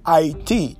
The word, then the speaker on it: Haiti: Haiti, that's the correct pronunciation for those of you who live abroad. The name is Haiti, God's people.